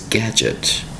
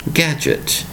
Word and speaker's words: gadget: Today's word is gadget. Gadget. Two distinct syllables for the word gadget. Spelled G-A-D-G-E-T. Notice the spelling has the word letter D in there. Spelled G-A-D-G-E-T. E-T. Gadget. Gadget. Gotta hear that T.